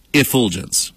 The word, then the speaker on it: effulgence: Infulgence. I'm sorry. Effulgence.